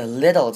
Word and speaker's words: little: 这边最后面的这一句呢，首先要注意的是 little 这个字，小的，一样还记得吗？中间放 tt 的时候，我们要念成弱化的 t 的声音，念成比较像是 d, 念成 little.